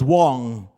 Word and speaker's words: Wang: But first things first, my name is Solomon Wang, pronounced as Wong. It's W-A-N-G, pronounced as W-O-N-G. And if you say Wang, that really would be Wong, all right?